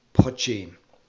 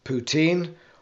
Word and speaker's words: poitín: Break it into its two syllables. The first is poch, like much, and the second is een, like bean. When you put them together, you get poitín.